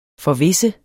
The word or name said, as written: forvisse